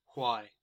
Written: why

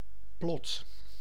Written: plot

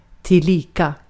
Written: tillika

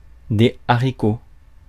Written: haricots

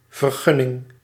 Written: permit

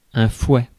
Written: fouet

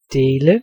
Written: share

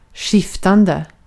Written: skiftande

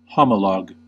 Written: homologue

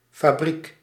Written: factory

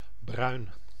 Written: brun

